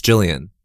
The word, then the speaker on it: Gillian: I think it's Gillian. Soft G.